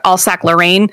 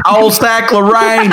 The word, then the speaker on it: Alsace-Lorraine: Well, I was one time when I went to France, I was staying at this old abbey near Alsace-Lorraine, which is the most American way to say that because I don't know how to say it. – Alsace-Lorraine.